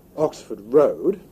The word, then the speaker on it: Oxford Road: Kaj mi devas diri al vi, ke la reguloj de la angla lingvo postulas, ke vi akcentu road, Oxford Road, sed ke vi akcentu Oxford en Oxford Street. Ĉar tiuj du vortoj, pli malpli sinonimaj, tamen sekvas malsamajn principojn de akcentado.